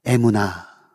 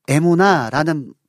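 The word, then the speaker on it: 에무나 (אמונה): 에무나. 여러분, 이 에무나라는 이 단어는요, 어떤 의미를 갖고 있냐면 신실하심, 확고부동하심, 진실하심을 의미합니다.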